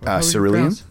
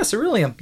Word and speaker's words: Cerulean: I really enjoyed Ceruleum. Is that how you pronounce that? – Cerulean, I think it is. – Yeah, Cerulean. Yeah, Cerulean.